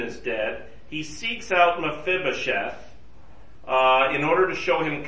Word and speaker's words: Mephibosheth: Yes, Mephibosheth. That's how you pronounce it, Greg. Mephibosheth, yes.